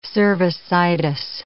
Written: cervicitis

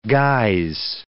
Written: guise

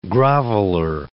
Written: groveler

